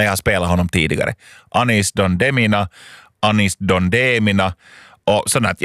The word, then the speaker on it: Anis Don Demina: och jag frågade att hur uttalar man den här artistens namn, och jag tror att de sa Anis Don Demina.